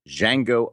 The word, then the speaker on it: Django: Well, the number one movie around the world is Quentin Tarantino's Django Unchained, The D is Silent.